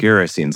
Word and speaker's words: Gerasenes: Well, Gerasenes, like kerosene.